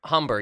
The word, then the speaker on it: Humber: Humber, yeah. – Okay. – Go ahead. Wait, it rhymes with lumber.